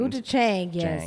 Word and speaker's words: gochujang: Gochujang. Did I say that properly? – It's a, I think, jang at the end. – Gochujang, yes. – Jang, yeah.